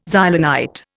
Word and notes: Xylonite: Indian

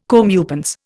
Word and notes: comeuppance: Indian

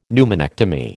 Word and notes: pneumonectomy: American English

